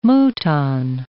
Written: mouton

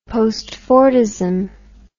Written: postfordism

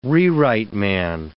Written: rewrite man

